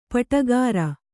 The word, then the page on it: ಪಾಡುಗಾರ: pāḍugāra